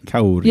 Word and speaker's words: Kauri: Kauri. – Yeah, kind of like that. Kind of like pole draws itself out. – Is that the Maori pronunciation? – Yes, that is the Maori. Yes, correct.